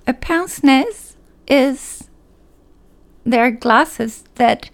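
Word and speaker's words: pince-nez: I know it's a weird thing to start a Mother Knows Best with, with Pince Nez.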